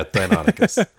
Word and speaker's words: Deinonychus: I'm not going to say this right. Exactly. Deinonychus? Is that how you say it? – Yeah, Deinonychus.